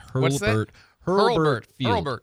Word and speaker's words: Hurlburt Field: Hurlburt Field.